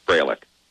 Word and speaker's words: Freilich: It's German. It's Freilich.